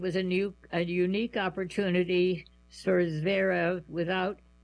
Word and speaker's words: Zverev: Finally, in France, Alexander Zverev, that's Z-V-E-R-E-V, I hope I'm saying it right, Zverev, is no longer one of the best players never to win a major title.